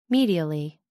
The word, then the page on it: medially: American English